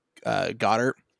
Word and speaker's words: Goddard: I think it is pronounced Goddard, like the dog from Jimmy Neutron.